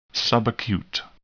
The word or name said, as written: subacute